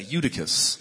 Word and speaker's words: Eutychus: Say his name, it's fun to say, Eutychus. It's like saying Francisco.